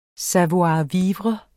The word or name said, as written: savoir-vivre